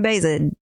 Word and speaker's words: Baeza: Or you can hit me on my inbox at Jasmine Baeza.